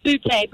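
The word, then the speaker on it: Butti: Yeah, it's pronounced Bute, but it's Duty.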